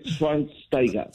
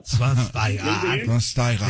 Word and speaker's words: Schweinsteiger: Después de 37 años de vida, ya sé cómo se pronuncia. Bastian Schweinsteiger. – Schweinsteiger. – Sí, muy bien.